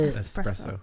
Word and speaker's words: espresso: I know it's spelled with an S. Is that how you pronounce it, though? – Yeah, there's no X in it. – You're espresso.